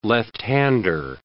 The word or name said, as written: left-hander